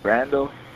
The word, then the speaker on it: Randall: Randall Thomas. – Randall Thomas. Can you spell that for me? – Randall, R-A-N-D-A-L-L, Thomas, T-H-O-M-A-S.